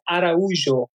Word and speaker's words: Araujo: Yes, it's Araujo.